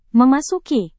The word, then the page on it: memasuki: Indonesian